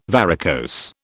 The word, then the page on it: varicose: British English